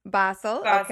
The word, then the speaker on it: Basel: She now lives in, I should have asked you how to pronounce this, Basel, Switzerland? – Basel.